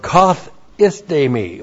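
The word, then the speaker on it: kathistemi (καθίστημι): And then the verb kathistemi. We're going to have this used twice in this verse. Looks like that in the Greek, K-A-T-H-I-S-T, long E-M-I, kathistemi.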